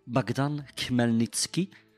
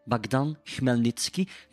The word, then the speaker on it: Bogdan Khmelnytsky: Bogdan Khmelnytsky. Nog eens, Bogdan Khmelnytsky.